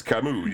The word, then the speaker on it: Camus: It's Camus or Camus? – It's Camus, yeah.